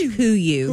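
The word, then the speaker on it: KUIU: It's pronounced Kuyu. – Kuyu.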